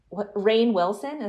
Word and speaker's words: Rainn Wilson: Rainn Wilson, is that how you say his name? – Oh, yeah.